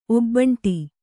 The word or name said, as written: ಒಬ್ಬಂಟಿ